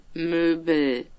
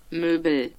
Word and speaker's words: Möbel: This is how you pronounce furniture in German. – Möbel. Möbel.